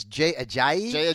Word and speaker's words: Ajayi: I do worry a little bit about – now, help me pronounce this, Jay Ajayi.